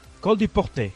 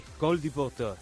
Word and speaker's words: Col du Portet: ¿Cómo se dice? A ver. – Col du Portet. – Col du Portet.